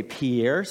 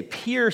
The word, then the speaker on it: Pierre: maybe you would say Pierre, South Dakota, but that's not how they say it over there. They say Pierre, South Dakota.